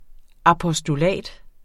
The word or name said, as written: apostolat